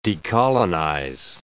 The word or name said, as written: decolonize